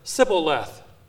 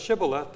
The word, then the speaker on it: Shibboleth: They mispronounced the word and said Sibboleth. Instead of Shibboleth, they say Sibboleth. They couldn't do the SH sound.